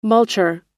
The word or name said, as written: multure